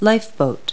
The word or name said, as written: lifeboat